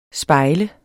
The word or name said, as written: SPEJLET